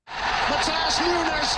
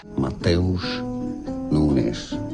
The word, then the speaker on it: Mateus Nunes: Matthias Nunes, volleying Wolves in front. – Mateus Nunes. Mateus Nunes is how it's said in Portuguese. So in English, rather similarly, you don't have a TH sound.